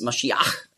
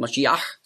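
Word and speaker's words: Mashiach (משיח): Also darum wurde aus Masch, wurde Mess, Und dann Iach mit dem Het am Schluss, Mashiach, hat man früher ausgesprochen, Mashiach.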